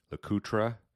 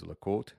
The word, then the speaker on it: LeCoultre: And then the last part, you know, if you talk to old collectors, they'll call it LeCoultre, but really today most people pronounce it like the French would, which is LeCoultre. So that last bullet really is the correct pronunciation from a French-speaking side, Jaeger-LeCoultre.